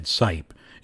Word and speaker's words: Seipp: Back in 1854, Conrad Sipe, and it's spelled S-E-I-P-P, but it's pronounced Sipe, he founded one of the first breweries in Chicago, and the beer was probably one of the most popular ones in Chicago.